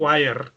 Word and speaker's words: choir: wire.